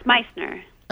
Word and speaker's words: Meissner: Sure, it's Meissner.